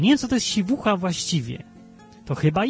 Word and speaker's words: siwucha: Pronounced siwucha. Siwucha po angielsku jest.